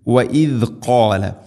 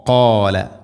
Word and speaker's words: قال: وإذ قال موسى وإذ قال وإذ ذلك ذا ذي ذو أذ وإذ وإذ قال وإذ قال إن الله പറയേണ്ടത് وإذ قال قاف സ്വരഭാരത്തിൽ ഉച്ചരിക്കേണ്ട അക്ഷരം ഖ ഖാല ഖാല എന്ന് പറയരുത് ഖാല അല്ല ഖ